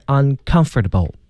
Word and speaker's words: uncomfortable: 对，这个重音呢，大家看是在那个 c 啊，这个字母 c 上，但是 c 呢读成 k 这个音，所以就是 uncomfortable。